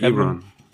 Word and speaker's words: Ebron: Wie wird er ausgesprochen? – Ja, Eric Ebron. – Ebron, okay, wir bleiben bei Ebron, korrigiert uns, wenn wir falsch liegen.